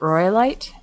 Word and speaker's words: Royalite: My name is spelled R-O-Y-A-L-I-T-E. So at Roylite, and just hit me up and say, hey, can you give me the link to sign up and I'll shoot you it.